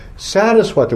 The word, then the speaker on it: Saraswata: Unto Deva, it's in the locket, we're offering obeisances to Saraswata with a long A.